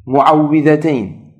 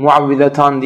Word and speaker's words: muavvizeteyn: O zaman Felak ve Nas surelerinin her ikisine de muavvizeteyn, muavvizetan diye de okunuyor ama daha genel olarak muavvizeteyn tabiri daha böyle yaygındır. Bu ifade de kullanılıyor inşallah.